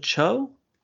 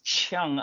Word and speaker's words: Cheung: And the performances of Tony Leung and Maggie, is it Cho? – It's Cheung. I mean, in Mandarin, it's Zhang, but of course, this is a Cantonese pronunciation.